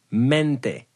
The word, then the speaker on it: mente: So the Spanish word of the day is mente. It's spelled mente. M-E-N-T-E.